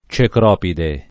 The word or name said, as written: Cecropide